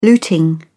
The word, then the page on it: looting: British English